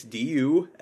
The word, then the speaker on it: Dushku: Am I saying it wrong? – No, it's just, okay. Like, Dushku. – Maybe I am. But it's Dushku.